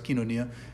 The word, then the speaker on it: koinonia: They continued steadfastly in the apostles' doctrine and fellowship, kinonia, as people, or koinonia, as they call it, but it's pronounced kinonia, fellowship, communion, and in the breaking of the bread and the prayers.